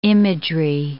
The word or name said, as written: imagery